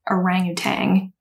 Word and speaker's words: orangutan: Oh, so I see here it says that the spelling and pronunciation of orangutan is not correct, or isn't incorrect. The NG at the end, that version has existed in English for longer than just the one ending in N.